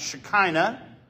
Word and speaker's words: Shekinah: So we're in the third installment of this study series, in which we're looking at this word right here that's called that's pronounced Shekinah.